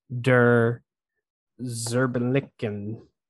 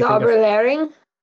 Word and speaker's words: Zauberlehrling: Der Zerbenlicken. I believe it's German, so I apologize to any German listeners if I said anything. – Zauberlehrling.